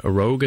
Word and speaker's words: Rouge: Rogue, I think he's pronounced it, but it's actually it's spelled rouge, like the French word for red.